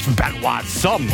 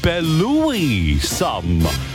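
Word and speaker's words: Belouis Some: Der heißt einfach nicht Benoit Somme oder sowas. Nein, es ist eigentlich ausgesprochen Belloui Somme. So wie Brother Louis.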